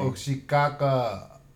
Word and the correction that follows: Naomi Osaka: Oh, I just pronounced it wrong. What is it? – Naomi Osaka. Yeah, then I think she lit the torch.